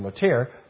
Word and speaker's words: Motyer: Motier, M-O-T-Y-E-R, Americans see that name and they say, Alec Motyer. And so they don't know who I'm talking about when I mention Motyer. Well, it's actually pronounced Motyer.